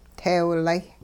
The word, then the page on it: teewlay: Hupa